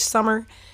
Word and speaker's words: Sommer: Morgan Krantz, Rich Sum Sommer, as I was saying, I think I said his name wrong earlier. Rich Sommer, um, and all, and of course Thamela Mpumlwana, um, uh, who plays young our teen, young Tyson Parker.